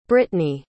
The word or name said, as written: britney